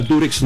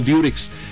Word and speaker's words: Durex: Así se llama Durex, no Durex. Lo que pasa es que nosotros pronunciamos, al decir Durex, uh-huh, pronunciamos la U como se pronuncia en inglés, U, okay, ¿no?